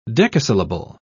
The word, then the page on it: decasyllable: British English